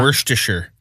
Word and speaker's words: Worcestershire: Worcestershire sauce? Is that how you pronounce it? I always say wrong. – Worcestershire. – Worcestershire. – Worcestershire. – It's the worst.